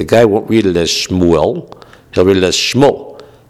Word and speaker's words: Shmuel: But a lot of people pronounce it as Shmuel. Right? Shmuel, that's how you get Shmuley, right? The nickname for a kid, a man named, or a boy named Shmuel, is not Shmueli, it's Shmuley. How do you get the Shmuley? You get the Shmuley because the name is pronounced Shmuel, right? You sort of slur it.